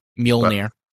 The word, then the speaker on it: Mjolnir: I realize it wouldn't be the God of War Mjolnir, and I know I'm butchering the pronunciation. – Mjolnir, but I don't care. – You're pretty close. I mean, the pronunciation is Mjolnir.